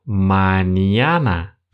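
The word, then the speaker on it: mañana: Если мы нарисуем небольшую волну над буквой «н», то тогда она становится буквой «эния». И по звучанию она напоминает сочетание «н» с мягким знаком, знаменитое для многих слово из испанского языка «маньяна» пишется именно с этой буквой. «Маньяна». Вот это «не» – это как раз-таки вторая буква «энье» в этом слове.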